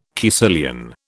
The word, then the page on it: caecilian: British English